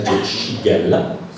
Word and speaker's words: Shigella: Poi c'è la Shigella, si scrive Shigella, ma si legge Shigella.